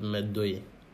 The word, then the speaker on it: Medoye: My name is Philip Medoye. – Medoye. – Medoye. – Medoye. – Medoye. Three syllables. – Okay, sorry. – M-E-D-O-Y-E.